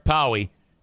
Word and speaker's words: Pauwee: I go, how do you say this name? Powie. P-A-U-W-E-E. Text or call Eric Powie at Oakland Auto Detail.